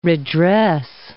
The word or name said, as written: redress